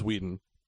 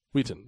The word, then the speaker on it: Wheaton: Is it with a T though? – It's with a T. – Okay. But it's pronounced Wheaton. – Wheaton.